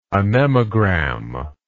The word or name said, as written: anemogram